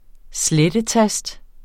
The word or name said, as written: slettetast